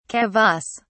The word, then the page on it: kavass: British English